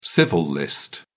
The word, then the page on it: civil list: British English